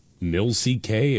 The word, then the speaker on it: Milck: And then somebody named, I guess it's pronounced Milk. Milck, it's all caps, M-I-L-C-K.